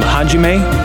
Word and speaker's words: Hajime: It was called Hajime.